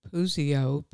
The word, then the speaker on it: Puzio: And we're also on YouTube, and it's under my name, Susan Puzio, P-U-Z-I-O, in case you don't know how to spell it.